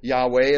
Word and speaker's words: Yahweh: That Lord in capital there is YHWH, or Yahweh, as we like to pronounce it.